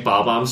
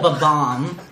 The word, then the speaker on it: Bob-omb: Instead of, like, doing a race and whatnot, you're just fucking launching Bob-ombs trying to... – You pronounce, like, everything in Mario wrong. You're putting the emphasis on the wrong syllable. – It's a Bob-omb. – Not a Bob-omb.